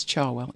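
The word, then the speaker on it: Cherwell: It's Charwell. If you ever visit, it's an A.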